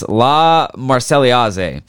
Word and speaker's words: La Marseillaise: And on the first day there, they knocked the fucking dicks off the French with a jazz rendition of, I'm going to fucking butcher this, La Marseillaise. – No, you nailed it.